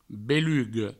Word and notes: beluga: Provençal; belugo

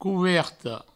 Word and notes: coverta: couverto

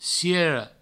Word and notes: ciera: ciero; provençal

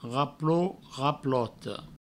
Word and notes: raplot, raplota: provençal; raplot, raploto

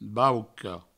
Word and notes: bauca: Provençal; bauco

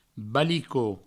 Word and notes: balico: balicot; Provençal

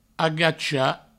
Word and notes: agachar: agacha

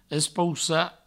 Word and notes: espoussar: provençal; espoussa

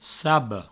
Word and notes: saba: sabo

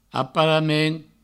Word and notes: aparament: aparamen

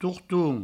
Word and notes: torton: tourtoun

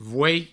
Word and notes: voei: vouei

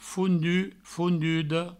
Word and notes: fondut, fonduda: foundu, foundudo; Provençal

